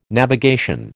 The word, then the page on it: navigation: American English